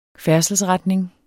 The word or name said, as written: færdselsretning